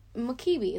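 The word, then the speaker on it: Mukiibi: Uh, so Mukibi is how it looks in English, right?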